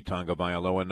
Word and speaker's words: Tagovailoa: It's not like Tagovailoa, which is spelt nothing like Tagovailoa, but turns out to be Tagovailoa nonetheless, as told to us by Lee Steinberg.